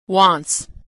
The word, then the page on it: wants: ending s